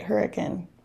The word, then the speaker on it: Hurricane: I know particularly in Hurricane, everybody, and I did say that right, Hurricane, for non-Hurricane listeners.